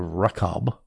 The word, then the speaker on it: Rechab: Malchijah, son of Rechab, R-E-C-H-A-B, Rechab is how I'm going to pronounce that, ruler over the district of Beth-Hakerem, repaired the Dung Gate.